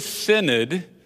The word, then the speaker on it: synod: In fact, my extended family shared the same synod, and we all knew how to spell it, synod, S-Y-N-O-D, and we knew how to pronounce it, synod, and we knew what it translated into, to walk together.